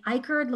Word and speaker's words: Icard: My name is spelled Michelle with two L's, and then Icard looks like iCard. It's I-C-A-R-D.